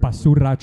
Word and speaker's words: Basura: Basura Triangle podcast. – Yeah, you don't need to roll the R, but I admire the hubris. – Where did the name come from? – So Basura is Spanish for trash.